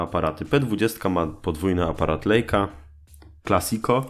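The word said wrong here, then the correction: Leica: Lajka. To się tak wymawia, purysto.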